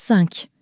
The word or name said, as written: cinq